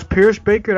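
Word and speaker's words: Pierschbacher: I don't know how to say Ross Pierce Baker. That's how you say his name, from Alabama.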